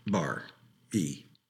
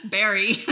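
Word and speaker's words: Barrie: Barr. E. – My J.M. Barry.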